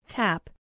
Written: tap

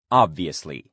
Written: obviously

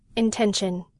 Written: intention

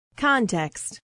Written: context

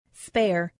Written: spare

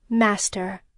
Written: master

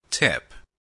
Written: tip

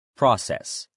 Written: process

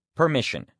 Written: permission

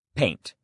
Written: paint

